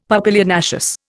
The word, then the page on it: papilionaceous: Indian